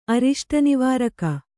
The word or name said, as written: ಅರಿಷ್ಟನಿವಾರಕ